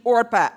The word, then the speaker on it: Orpah: Oprah was actually named Orpah at birth. Her family mispronounced it for the first year, so they changed it to Oprah. Okay, so she was Orpah, she's now Oprah.